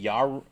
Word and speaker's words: Yarua: It's spelled Y-A-R-U-A. Yarua.